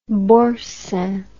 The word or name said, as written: boursin